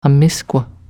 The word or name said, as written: amiskw